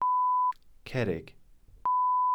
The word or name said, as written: cerrig